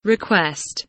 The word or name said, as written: request